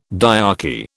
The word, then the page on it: dyarchy: British English